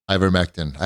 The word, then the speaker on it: ivermectin: Ivermectin? – Ivermectin.